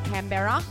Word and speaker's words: Canberra: And that's how you say it, Canberra. Canberra.